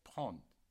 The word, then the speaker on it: prendre: And to take in French is prendre, but we will pronounce just prendre. Prendre. Do the D at the end, prendre.